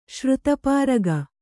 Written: ಶ್ರುತಪಾರಗ

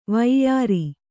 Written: ವೈಯಾರ

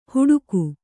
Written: ಹುಡುಗು

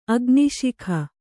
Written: ಅಗ್ನಿಶಿಖೆ

